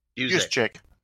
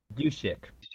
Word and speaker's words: Juszczyk: Juszczyk. – Juszczyk.